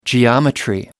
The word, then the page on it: geometry: American English